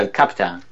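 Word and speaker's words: El Capitan: De la América Profunda sería el Captain.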